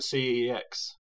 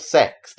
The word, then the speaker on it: CeX: I call it CEX. – It's officially called Sex. That's actually how it's pronounced.